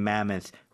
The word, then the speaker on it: mammoth: So, uncovering mammoth remains and ancient Roman ruins 라고 돼 있는데요. 일단은 마모스 아닙니다. 마모스 아니고요. 발음 정확히 해주세요.